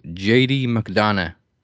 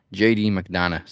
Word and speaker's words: McDonough: But, like, they've changed Jordan Devlin to JD McDonough, which I don't get. – Is that how you pronounce it? – Yeah. So it's like a silent G. Very Irish. JD McDonough.